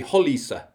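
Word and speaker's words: Roliça: But after the Battle of, now I must pronounce this right because you taught me how to pronounce it, the Battle of Valisa. – I think technically, even if we want to get really technical, I think it might be Holisa, but I thought that was a step too far.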